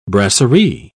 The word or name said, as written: brasserie